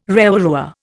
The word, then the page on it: rewarewa: Indian